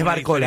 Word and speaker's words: Barcola: ¿Por qué decís Barcola y no Barcola, Gus? – Sí, porque creo que la pronunciación es así, no lleva tilde, en este caso, sí, Mbappé, por ejemplo, y bueno.